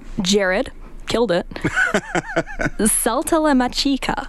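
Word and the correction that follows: Saltalamacchia: Okay, wait, let me try again. I know there's still K at the end of that. Saltalamachia. – Saltalamachia.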